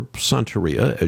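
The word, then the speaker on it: Santería: Well, and as a point of information, not being a Spanish speaker myself, when I read that word, Santeria, or Santeria, as you properly pronounce it more, excuse my Spanish accent, I hear the word Satan.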